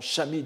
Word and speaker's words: Shami: L'auteur en est celui que l'on appelle Sami Manze ou Sami Manze, c'est-à-dire Manze, le moine novice, Sami, ou que l'on prononce plus correctement Shami dans les textes bouddhiques, mais c'est japonisé en Sami, c'est l'abréviation de Shramanera, n'est-ce pas, celui qui commence à faire effort dans la voie, le futur Shramana.